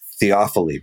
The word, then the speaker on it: theophily: And I think that's that's where the book takes us on this journey is to and then into this idea of it's theophily, right? Is that how you say it? Theophily. Yeah.